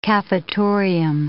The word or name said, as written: cafetorium